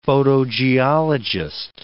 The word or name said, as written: photogeologist